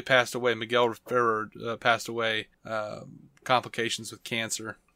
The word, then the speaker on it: Miguel Ferrer: Miguel Ferrer.